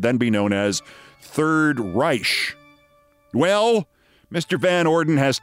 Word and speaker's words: Reich: Confronting economist Robert Reich, who spells his name R E I C H and pronounces it Reich,